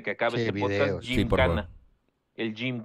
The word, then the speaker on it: Gymkhana: Jim Cana, GYM. Khana, Gymkhana.